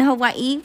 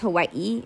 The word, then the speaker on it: Hawaii: I was in Hawaii for a week, and that's really how you pronounce it. I don't think the E has to be so high-pitched, but it is Hawaii. It's not Hawaii.